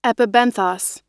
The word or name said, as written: epibenthos